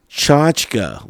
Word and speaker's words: Tchotchke: So if you know, if you don't know how to spell that, don't worry, because I didn't know how to even say it. I had to look online and go to a dictionary just to hear it. So let's see if you can. Tchotchke, ladies and gentlemen. That's how you pronounce that.